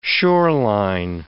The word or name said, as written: shoreline